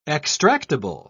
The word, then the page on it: extractible: British English